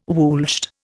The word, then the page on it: woolshed: Indian